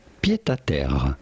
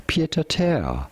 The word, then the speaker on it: pied-à-terre: In French, we would say pied-à-terre. A pied-à-terre. – Yep. – But it'd be fine in English to call it pied-à-terre.